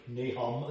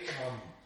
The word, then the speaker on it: Nahum: It's not technically Nahum, as we would say it in English. It would be Nahum. So it would be Nahum is how it would be pronounced, with a sound.